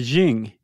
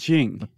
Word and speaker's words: Qing: How do you pronounce it with the Q? I think I would look that up. – I think it's, how do you spell it? – Jing, Q, Q-I-N-G. – Q-I-N-G. – Wait, the Jing. I think it's Jing.